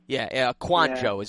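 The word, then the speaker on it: Kouandjio: Quanjo or something like that, right? – You're right about the ballpark. Quanjo is actually the exact pronunciation, yes. – Oh, that's not bad, Quanjo.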